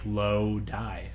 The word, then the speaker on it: Lodi: Now, he tells me how to pronounce it. Lodi. – Ooh. – I say he's a PS. Most people have that problem when pronouncing my town. It literally sounds like Lodi. So now we know it's Lodi.